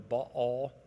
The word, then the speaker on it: Baal: She introduced worship to idols, other gods, Baal. Or Baal is another way to pronounce it, and it depends on which Old Testament prof you had.